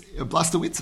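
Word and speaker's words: Blazkowicz: Blastowicz, am I saying it right? – Close enough.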